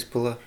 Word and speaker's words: Pullar: Chris Puller, yeah. – Not Pullar? – Well, it's not Pullar, but it's spelled AR, not ER.